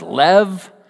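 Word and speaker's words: lev (לב): In Hebrew, heart is pronounced lev.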